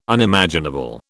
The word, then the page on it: Unimaginable: British English